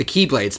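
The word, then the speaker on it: Keyblade (χ-blade): He was actually weird with the Keyblade. Spelled X-Blade, but pronounced Keyblade.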